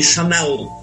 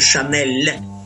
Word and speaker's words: Chanel: Во французском языке мягкое «л» считается, господа американцы, это не Шанель, это Шанель.